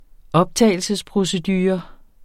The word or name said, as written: optagelsesprocedure